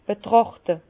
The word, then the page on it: betràchte: Bas-Rhin; Herrlisheim